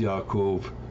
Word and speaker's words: Yaakov: if you want to say his name correctly, it's Yaakov. There is no J in the Hebrew alphabet, so, but anyway, I'm so thankful she brought this up.